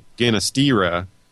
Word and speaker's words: Ginastera: I've always said Ginastera, and that in normal Spanish pronunciation would be correct. Ginastera, the G is normally pronounced huh, like an H. However, apparently he is of Italian descent and wanted it to be pronounced Ginastera.